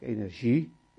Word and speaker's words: energie: Van Dalen meldt dat beide varianten mogelijk zijn, maar dat de ZJ-uitspraak, energie, in Nederland het meest logisch is, het meest gewoon is. Want dat is ook verklaarbaar, want energie, dat woord komt uit het Frans.